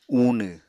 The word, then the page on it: uunw: Apatani